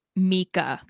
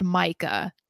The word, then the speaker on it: Micah: I did see because people were coming for you about Mika. They're saying it's pronounced Micah.